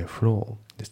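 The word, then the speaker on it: floor: あとまたフロアのところがさっきのストアと同じでフローですね。フロアっていう感じで、この R がねちゃんと発音されないんですよね、イギリス英語だからね。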